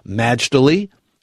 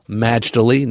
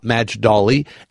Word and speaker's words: Majdali: And for the record, it's Majdali, exactly as it's written. It's pronounced with an accent mark on the first syllable, Majdali, not Majdali, and certainly not Majali.